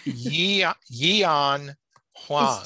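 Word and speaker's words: Yi-An Huang: It's Yian Huang. – Is it two syllables? Because I've heard people say Yan. – A lot of people just say Yan.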